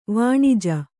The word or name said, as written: ವನಜ